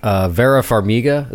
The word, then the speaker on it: Vera Farmiga: Like Vera Farmiga, is that am I saying that right? – Mm-hmm.